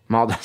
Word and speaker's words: Maldives: Maldives. – Maldives.